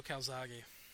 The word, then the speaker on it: Calzaghe: And, you know, I would love to see him take on Calzaghe. Is that how you pronounce it? – Yeah, I believe Joe Calzaghe.